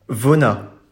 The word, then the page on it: Vonnas: French